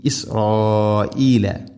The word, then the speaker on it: ഇസ്രായീല: ഇനി ഇസ്രായീല, ഹംസ കൃത്യമായിട്ട് ഉച്ചരിക്കണം. പലപ്പോഴും ഓതിപ്പോകുന്നതൊക്കെ ഇസ്രായീല എന്നാണ്, ഇസ്രായീല, അങ്ങനെയല്ല, ഇസ്രായീല.